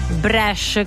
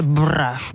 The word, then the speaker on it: Bresh: Bresh, Bresh. Fa un po' più rapper, un po' trapper, un po' di oggi, un po' non me ne frega niente. Bresh, Bresh. Io lo pronuncio così.